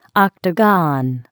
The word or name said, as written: octagon